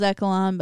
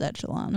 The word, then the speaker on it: Echelon: I thought it was Echelon, but it is Echelon.